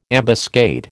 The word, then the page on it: ambuscade: American English